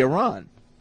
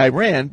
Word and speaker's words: Iran: So, you know, he would always say Iran. – Right, because that's how it's pronounced. – Right. But I say Iran because...